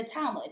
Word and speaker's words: Talmud: Yeah, in the Talmud. Yes, am I pronouncing that correctly? – Yep. Mm-hmm.